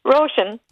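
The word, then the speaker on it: Róisín: Ich stand schon mal mit dir in einer Schlange vor einem Rochin Murphy Konzert. Oh. Wie wird die richtig, wie wird die richtig ausgesprochen? Roshan. Roshan, habe ich ja ganz gut gemacht eigentlich.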